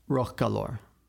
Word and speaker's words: Rochallor: um, Rochallor.